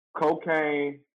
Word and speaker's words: Kocaine: Real quick, Quayla, before you go in, Cocaine, before you, Quayla, before you go in, Cocaine, you said Cocaine, it's just like it's spelled right here, right? – Mm-hmm. – No dots, no nothing, right? – It's Cocaine dot Barbie with a 3. So Cocaine, K-O-K-I-N-E dot Barbie, B-A-R-B-I.